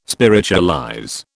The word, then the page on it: spiritualize: British English